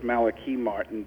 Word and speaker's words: Malachi Martin: Well, Lucifer's Lodge came about when I met Father Malachi Martin, which he actually pronounced as Malachi Martin, but he didn't care if people pronounced it different ways.